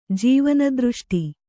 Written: ಜೀವನದೃಷ್ಟಿ

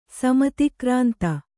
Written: ಸಮತಿಕ್ರಾಂತ

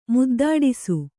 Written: ಮುದ್ದಾಡಿಸು

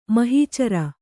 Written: ಮಹೀಚರ

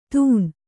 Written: ದೂನ

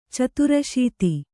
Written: ಚತುರಾಸೀತಿ

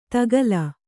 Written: ತಕ್ಕಿಲ್

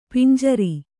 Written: ಪಂಜರ

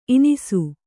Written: ಈನಿಸು

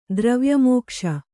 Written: ದ್ರವ್ಯಮೋಕ್ಷ

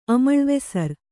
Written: ಅಮಳ್ವೆಸರ್

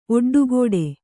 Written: ಒಡ್ಡುಗಟ್ಟು

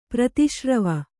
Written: ಪ್ರತಿಶ್ರವ

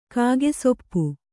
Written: ಕಾಗೆಸೊಪ್ಪು

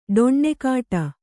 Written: ಡೊಣ್ಣೆಕಾಟ

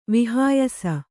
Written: ವೈಹಾಯಸ

